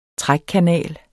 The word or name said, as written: trækkanal